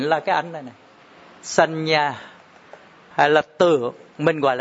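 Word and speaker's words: saññā: Lấy cái từ là tưởng, tiếng Việt cho mọi người dễ hiểu, tiếng Pali dễ như sanh nhà, đọc là sanh nhà. À, có hai cái dấu ngã đọc thành chữ nhờ.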